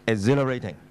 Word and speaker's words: exhilarating: 原来咧第三个 H 字啊，EX HIL 嗰度咧个 H 字系唔发音嘅，所以呢个字咧只系读。 exhilarating，令人非常之雀躍興奮。